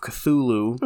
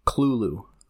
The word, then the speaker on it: Cthulhu: Fun fact for you, not pronounced Cthulhu. – How's it pronounced? – Clulu. – Clulu? – Yeah. – Interesting. – Yeah. – Hmm. – Everyone says Cthulhu. – That's the way it's spelled. – Yeah. It's Clulu.